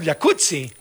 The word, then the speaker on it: jacuzzi: Entonces deberíamos pronunciar el jacuzzi. – Exacto. – Como pizza. De... como Rampazzo, como Claudia Rampazzo.